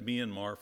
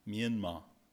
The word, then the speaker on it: Myanmar: One of the things is, how do you say Myanmar? I've said it Myanmar for years, and I just found out it really comes from two words in Burmese, fast and strong. So it's really pronounced with two syllables and no R. So it's something like Myanmar, something like that. Two syllables, Myanmar.